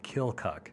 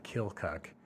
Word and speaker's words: Keokuk: But yeah, you talk to the folks that grew up in that area, they'll pronounce it Keokuk. – Is it Keokuk or is it Keokuk? – Well, I think if you're not from that area, it's Keokuk, kind of how it's spelled out.